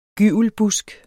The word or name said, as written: gyvelbusk